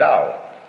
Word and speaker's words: Do: And it shows you how to do more with less. It is, of course, like Zen, in common with Zen, the philosophy underlying Judo. Do is the Japanese way of pronouncing the Chinese word Dao.